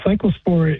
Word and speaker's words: cyclospora: Is it cyclospora? Am I saying that correctly? – Yeah, cyclospora is a parasite that is the agent that causes the disease.